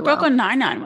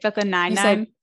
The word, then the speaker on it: Brooklyn Nine-Nine: You emphasize a different part of that show's title than I would. – How did I say it? Brooklyn Nine-Nine? – Yeah, you said Brooklyn Nine-Nine.